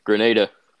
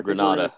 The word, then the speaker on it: Grenada: All right, Grenada. – Yeah, or Granada, however you want to pronounce it.